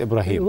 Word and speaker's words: إبراهيم: النطق في جميع الحالات إبراهيم.